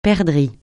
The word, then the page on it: perdrix: francais